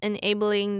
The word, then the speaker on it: enabling: 然后这边 A 要发长音, 所以是念 enabling.